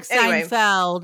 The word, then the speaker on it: Steinfeld: I'm really enjoying Haley Steinfeld. Is that how you say it? I don't know. I'm asking Steinfeld. – The wrong person on how to. – Steinfeld. Steinfeld. Yeah.